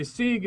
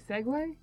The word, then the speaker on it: segue: Okay, so I've always pronounced it. Yeah, Sieg. Segway. – Segway. – Sieg. – How's it spelled? – Yeah. – S-E-G-U-E. – Yeah. – Segway. Yeah. – Segway. Is there a pronunciation button? – Yeah, there's a pronunciation thing in. – I've never heard it pronounced Sieg.